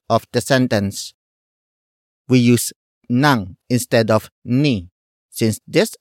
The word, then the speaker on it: nang: The next word is nang, which is spelled as ng.